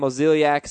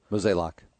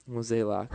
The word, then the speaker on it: Mozeliak: And here's what St. Louis GM John Mozeliak said on Oscar Tavares. Quote, Mozeliak. – I knew I didn't say it right. Mozeliak.